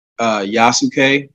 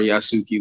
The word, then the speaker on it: Yasuke: I am working on the soundtrack for a movie called Yasuke or Yasuki, whichever way you want to pronounce it, which is the first like African samurai, if you will.